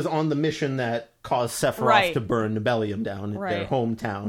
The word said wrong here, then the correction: Nibelheim: Nibelheim is definitely the way to pronounce it. – Yeah, because it's based on Norse.